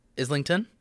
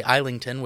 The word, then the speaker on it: Islington: I swear to God, I visited every single pub on my block in Islington. Which, if you say Islington, which is how it's spelled, you would get probably crucified. So don't do that if you're ever in Islington.